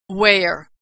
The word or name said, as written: wear